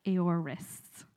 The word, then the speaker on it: aorist: In the Greek, it's called the aorist. Is that right? Not. I had to run and ask Jesse a minute ago, just before starting, to ask how to pronounce it. Aorist.